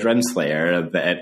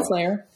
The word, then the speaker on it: Renslayer: Yeah, because I'm also, part of this question for me has to do with the question that Loki poses to Judge Renslayer is, did I get the name right on that? – You did, Ravonna Renslayer.